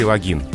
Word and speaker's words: логин: А надо ввести логин, только такое ударение.